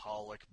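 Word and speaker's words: xxxHOLiC: I know that it's supposed to be pronounced Holic, but if you want me to read it like that, don't put X's in front of it.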